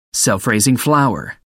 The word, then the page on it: self-raising flour: American English